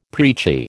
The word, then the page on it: preachy: American English